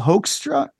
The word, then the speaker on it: Hoekstra: Blades and Aldrich aren't there anymore, but it's Jeff Pilson on bass from Foreigner with Joel Hoekstra. Am I saying it right? – I think so.